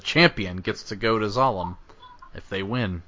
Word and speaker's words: Zalem: Oh, by the way, I googled it, you're right, it's spelled Z-A-L-E-M, so Zalem, not sure. – I just pronounced it how I would Salem, like Salem, Massachusetts, with the S instead of, or with a Z rather than an S.